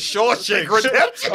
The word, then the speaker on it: Shawshank Redemption: Shank Shaw's Redemption. Remember when he. Shank Shaw. – Y'all both said it wrong. It's Shawshank Redemption.